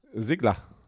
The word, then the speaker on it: Ziegler: Sigler. So wird ausgesprochen. Der, das wird nicht Siegler ausgesprochen in Amerika.